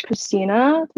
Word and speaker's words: Kristina: And my name is Kristina, which is spelled with a K, and Monsanto, M-O-N-S-A-N-T-O.